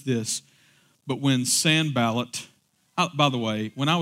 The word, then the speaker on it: Sanballat: Like when Sanballat the Horonite, and that is correct pronunciation,